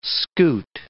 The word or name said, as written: scoot